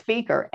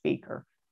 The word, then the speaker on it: Fieker: Because I knew it was a solicitor because it was Fieker. And if you mispronounce it. And so I thought the caller was a solicitor because she didn't pronounce my name as Fieker.